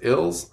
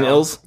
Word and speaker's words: Ils: I think it's pronounced Ills, I-L-S. It's foreign.